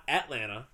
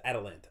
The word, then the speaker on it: Atalanta: Not Atlanta. – Yeah, no, it's spelled wrong. – Atalanta. No, it's Atalanta.